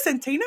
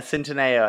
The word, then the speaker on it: Centineo: So um Noah Centino, is that who you said? – Yeah, Centineo, I think. I think that's Centineo. how you say it.